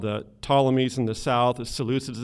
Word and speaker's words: Ptolemies: That's the silent P. The Ptolemies